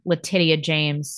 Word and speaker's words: Letitia James: Which is what Letitia James sought in her original complaint last year.